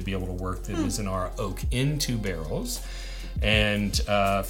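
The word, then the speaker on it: Mizunara: And this is the, I always say this wrong. Is it Mizanara? – Mizanara. – Mizanara finish.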